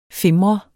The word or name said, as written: FIMREDE